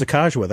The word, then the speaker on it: Sacagawea: Because she kept going, oh, it's Sacagawea. That's how it's pronounced.